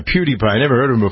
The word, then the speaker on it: PewDiePie: PewDiePie. – Is that how you pronounce it? – Yeah. – PewDiePie. – Like PewDiePie, but PewDiePie.